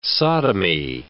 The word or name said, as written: sodomy